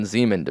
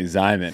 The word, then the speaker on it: Zyman: Yeah, or Ryman Zeman, depending on how you pronounce it. – It's definitely Zyman, because it rhymes with... – Ryman.